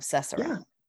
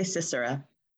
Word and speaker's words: Sisera: How do you say it? – I say Sisera. – Sisera. All right, there we go. That's what we're going to say, because that makes more sense.